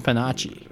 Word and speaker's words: Pinocchio: We need to pronounce it with the Italian pronunciation, Panacci.